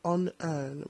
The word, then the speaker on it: Honear: On Earn, which is spelled HONEAR.